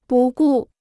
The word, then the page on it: 不顾: bù gù